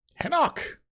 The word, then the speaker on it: Henoch: Henoch? Almost like Enoch, but different.